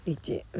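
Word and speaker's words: 立直: 立ち置くと書いてリーチっていうのもよくわかんない、まあ、リーチはまだわかるわ、規律の律に痴漢知見の地でしょリーチ、うん、まあわかるんですけどね。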